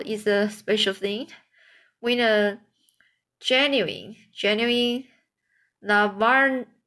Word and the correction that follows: Navajo: Navajo blanket.